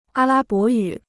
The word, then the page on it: 阿拉伯语: ā lā bó yǔ